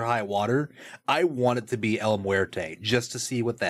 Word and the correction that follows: El Muerto: I'm sorry, El Muerto. I was mispronouncing.